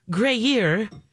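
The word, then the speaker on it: Gruyère: And so they go and try all these cheeses, and the winner this year is a matured Gruyere made of raw cow's milk from Switzerland. Yeah. – Okay, two things. First, did I say it right? – You did.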